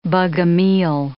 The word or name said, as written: Bogomil